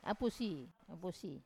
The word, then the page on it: apusi: Wamesa